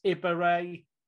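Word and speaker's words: Ibehre: No, there's no M. Do you want to say it properly on the pod? I kind of like assumed that if you were saying it incorrectly, you'd want to correct it. – Absolutely, it's, yeah. – It hasn't got an M in it. It's Ibaray.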